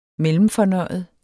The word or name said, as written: mellemfornøjet